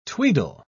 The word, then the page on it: tweedle: British English